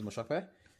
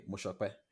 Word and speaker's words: Mosope: Uh, my name is Mosope. If you are listening on the African continent, I'll pronounce it like you know the Nigerian way, Mosope, that way.